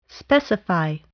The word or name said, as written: specify